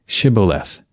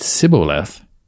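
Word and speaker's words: Shibboleth (שיבולת): If he said, "No," then they said to him, "Now say Shibboleth." And if he said Sibboleth, for he couldn't manage to pronounce it right, then they seized him and killed him at the fords of the Jordan.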